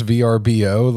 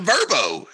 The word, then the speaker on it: VRBO: Like, are we so stupid that we can't pronounce VRBO? Like, who's ever called it Verbo? It sounds like furball or something, or some like kid's toy or something.